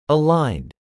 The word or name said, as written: aligned